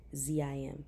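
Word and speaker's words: Baal-perazim: And that place was called Bel Prazim. That place was called Bel Prazim. I love that. I had to go and look up the pronunciation because it's Balprazim.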